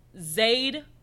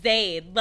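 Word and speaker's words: Zade: But she specifically says when she introduces herself that it is Zayd, which rhymes with aid. – Yes. Aid with a Z.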